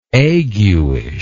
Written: aguishly

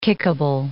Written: kickable

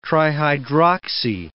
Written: trihydroxy

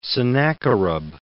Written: Sennacherib

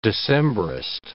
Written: Decembrist

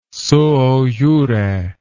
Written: suo jure

